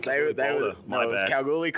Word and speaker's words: Kalgoorlie: Kalichi. I have to stop you there because you're butchering this stuff. Because Kalgoorlie College... Kalgoorlie College.